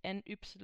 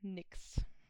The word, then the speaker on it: NYX: Ähm, das wird übrigens tatsächlich Nix ausgesprochen. Nicht NYX oder nicht Nyx, sondern Nix. Habe ich selber auch erstmal gelernt.